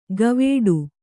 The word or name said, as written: ಗವೇಡು